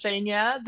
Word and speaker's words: Zhenya: You're actually pronouncing it perfect. So it's Zhenya, the same as you just pronounced it.